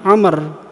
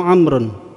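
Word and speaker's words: Amr (عمرو): Kalau setelah tulisan apa itu mim apa ain mim dan ra itu ada wawu, ya, itu bacanya Amr atau Amrun. Tapi kalau enggak ada wawunya, ya, di belakangnya, itu dibaca Umar. Jadi wawu pada lafaz Amrun itu hanya sebagai tanda untuk membedakan mana yang dibaca Umar, mana yang dibaca Amr.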